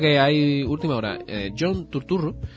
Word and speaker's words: Turturro: Sí, sobre todo teniendo en cuenta que es americano y tiene que pronunciarlo en plan Turturro. Turturro.